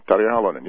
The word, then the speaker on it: Tarja Halonen: Your nation has a woman as president, Tarja Halonen. Am I pronouncing that right? – Yes, Tarja Halonen, yes.